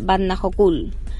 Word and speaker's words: Vatnajökull: Comenzamos nuestro quinto día del road trip por Islandia en Skatafell, en el Parque Nacional de Vatnajökull.